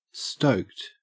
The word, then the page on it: stoked: Australian English